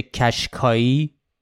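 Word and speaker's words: Qashqai: چون تلفظش میشه کشکایی یعنی یه ذره شبیه کشکاو میشه کشکاو یه اصطلاحی در واقع مثل انگار مرغ تخم طلا که یعنی یک منبع پولساز اصطلاحش میشه کشکاو چون شبیه اون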